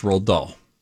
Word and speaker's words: Roald Dahl: Roald Dahl? – Yes, Roald Dahl.